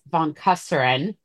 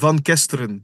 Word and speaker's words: van Kesteren: We are honored to have Bus von Kesteren, the global head of e-commerce at Sanofi.